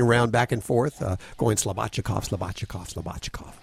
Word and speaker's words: Slobodchikoff: Actually, Judy told me before the show this morning, she said, it's Slobodchikov. And this guy's been on before, and apparently the first time, I didn't pronounce it exactly right.